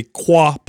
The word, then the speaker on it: QUAH: Every Sunday, we post a QUA post, Q-U-A-H. Don't worry. That represents Q&A. We pronounce it, so it says QUA.